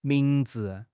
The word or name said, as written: ming2 zi5